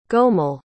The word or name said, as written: gomel